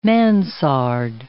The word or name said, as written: mansard